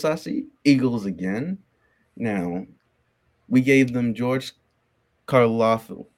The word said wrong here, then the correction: Karlaftis: George, George Karlaftis.